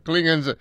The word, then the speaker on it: Klingon: It got kind of weird, but we're going to talk about Klingons or Klingons. It's supposed to be pronounced Klingon, but if most people watch that first series, most of the actors, including Shatner, always call them Klingons.